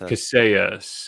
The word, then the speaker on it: Kaseya: That's Kaseya. K-A-S-E-Y-A. Kaseya. There's Kaseya.